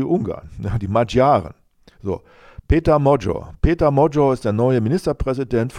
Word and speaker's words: Magyar: Sein Herausforderer, Peter der Ungar, so heißt er, Peter Magyar, ausgesprochen wird es Mojo, ja, Magyar, die Magyaren, das sind die Ungarn.